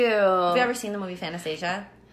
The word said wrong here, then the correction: Fantasia: Am I saying it? – I think you're saying it wrong. Mandela Effect. – Fantasia. Fantasia.